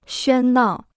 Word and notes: 喧闹: xuān nào

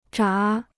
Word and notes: 闸: zhá

